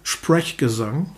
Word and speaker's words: Sprechgesang: Ähm, die englische Kritik sagt wohlwollend zu dem Album Sprechgesang, mhm. wörtlich, was es ganz gut trifft, mit Betonung auf der ersten Silbe.